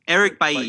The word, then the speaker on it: Eric Bailly: Eric Bailly.